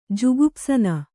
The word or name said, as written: ಜುಗುಪ್ಸನ